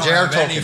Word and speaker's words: Tolkien: Did I say that right? – Well, no, yeah, J.R.R. Tolkien.